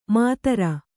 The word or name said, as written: ಮಾತರ